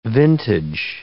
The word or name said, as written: vintage